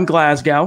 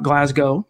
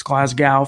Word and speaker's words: Glasgow: Graham Glasgow, which is how I believe it's pronounced. It's not Glasgow, it's Glasgow from what I've been told.